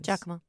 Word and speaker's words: Giacomo: I'm not sure how you pronounce the name, Giacomo, saying that he Skyped with him for four hours. – Giacomo. – Yeah. Giacomo. Mm-hmm. G-I-A-C-O-M-O.